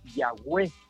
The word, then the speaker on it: Yahweh: Los antiguos escritores, los antiguos adoradores a Dios, aleluya, encontraron una palabra llamada, unas consonantes que se pronunciaban Yahweh. Luego los grandes eruditos, gloria al Señor, pusieron unas vocales y apareció la palabra Jehová.